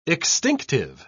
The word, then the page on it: extinctive: British English